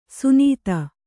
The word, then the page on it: ಸುನೀತ: sunīta